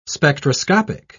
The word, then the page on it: spectroscopic: British English